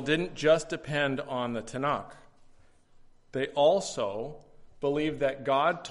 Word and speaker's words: Tanakh: So we have the Old Testament in our Bibles, and in the Jewish Bible, it's called the Tanakh.